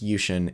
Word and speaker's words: Yuxin: I've been told it's pronounced Yuxin. It's not Yuxin.